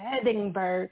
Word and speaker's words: Edinburgh: It's Edinburgh.